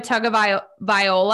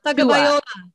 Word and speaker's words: Tagovailoa: So Alabama, Bama, the University of Alabama had Tua Tagovailoa. I can always never say his name. – Tua Tagovailoa.